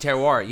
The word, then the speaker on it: terroir: I'm going to butcher this pronunciation. Terroir. – Terroir, yeah, 100%.